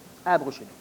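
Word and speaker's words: Abd-ru-shin: Quelle est la véracité des enseignements de Abba ou Sem? C'est mal prononcé. – Abrouchine.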